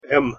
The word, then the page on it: м: Russian